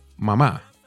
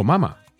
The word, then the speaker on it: Mama: Luego tenemos al gran cartagenero Joe Arroyo con su canción Mamá, o Mama, como lo pronuncia él en la canción, grabada en 1991.